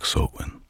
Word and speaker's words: Samhain: Пишется и известен у нас под названием Samhain, но на самом деле произносится как Sowen.